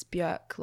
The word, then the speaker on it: Björk: Yeah, I think she said in an interview once it's Björk, like jerk.